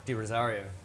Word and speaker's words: De Rozario: How do you pronounce it? – Di Rosario. – That sounds better.